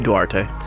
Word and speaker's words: Duarte: Durante? Is that how you say his name? – Duarte.